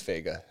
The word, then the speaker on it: Veiga: It's Veiga.